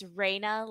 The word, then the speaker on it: Rayna: I always say it's Rayna, like ray of sunshine, not rain on your parade.